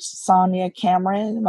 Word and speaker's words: Sonia Cameron: They can just search Find a Job, or they can search Sonia Cameron. My name is spelled S-O-N,